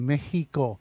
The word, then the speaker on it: Mexico: Mexico is actually pronounced Mexico with an H. The X has an H sound.